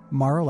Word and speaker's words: Morrow: Morrow, like tomorrow.